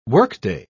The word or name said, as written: workday